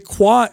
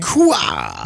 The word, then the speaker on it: Quah: Now today's episode is a Quah episode. That's how you pronounce Q and A. – Quah.